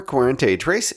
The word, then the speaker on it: Cuarenta y Tres: Liqueur Cuarenta y Tres is a Spanish liqueur. That's why I'm pronouncing the name in Spanish rather than 43 in English.